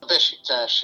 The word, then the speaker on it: Beşiktaş: Is it Beşiktaş? – Beşiktaş, yeah.